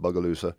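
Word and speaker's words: Bogalusa: I'm riding into Bugaloosa. That's how you say it, Bugaloosa, Alabama, Louisiana.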